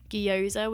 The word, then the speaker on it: gyoza: I remember how to pronounce this because it's gyoza without the gi, like the Y.